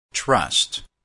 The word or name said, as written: trust